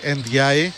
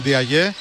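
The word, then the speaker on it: Ndiaye: Να συμφωνήσουμε σιγά σιγά σε κάτι, γιατί θα έρθετε αύριο μεθαύριο και όλοι θα το λέτε όπως εγώ, ότι ο ίδιος ο Σενεγαλέζος το προφέρει Εντζάι, το επώνυμό του. Άρα εγώ ακολουθώ τον Σενεγαλέζο. Αν δεν το ξέραμε, αν δεν το είχαμε δει το βίντεο, θα τον έλεγα ειλικρινά Ντιαγέ, όπως τους ξέρουμε να τους λέμε τους Σενεγαλέζους. Αλλά εφόσον το λέει Ντιαγέ, εγώ θα τον λέω έτσι.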